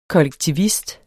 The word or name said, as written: kollektivist